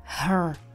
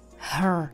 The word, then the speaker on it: her: Americká angličtina zní hrčivěji a tohle slovíčko by znělo her. Her. Jako hr na ně. Her. Takovej náznak toho R. Her. Ale já se zaměřuju na tu britskou, kde výslovnost bude her name.